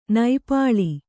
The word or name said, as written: ನಿಪ್ಪಲು